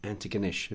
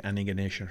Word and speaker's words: Antigonish: They don't pronounce it like that down there. They say Antigonish. – Antigonish. – Huh? Something like that.